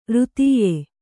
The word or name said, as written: ರಥ್ಯ